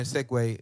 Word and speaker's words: segue: So like we're, we're gonna segue. Did I say that right this time? Yeah, you said it right.